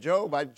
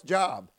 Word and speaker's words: Job: When they said, turn to the book of Job, I said, Job. Oh, it's Job.